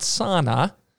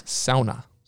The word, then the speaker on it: sauna: For those of you who are not Finnish, what I just said is the proper way to pronounce sauna. That. It's not sauna. It's sauna.